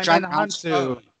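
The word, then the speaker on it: Djimon Hounsou: Oh, John Hansu.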